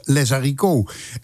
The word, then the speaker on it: Zydeco: Tenminste, dat spreken we zo uit. Maar je moet het eigenlijk uitspreken als Les Haricots. En als je dat nou maar genoeg creool uitspreekt, dan krijg je waarschijnlijk Les Haricots.